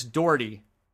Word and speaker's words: Daugherty: Daugherty? – I believe it's. I was told recently that it rhymes with 40. Chris Daugherty.